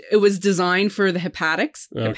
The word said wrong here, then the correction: haptics: Hepatics? Did I say that right? – Haptics. – Thank you.